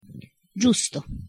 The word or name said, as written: giusto